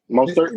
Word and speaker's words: Mostert: Is it Mustard? Mostert? – Mostert.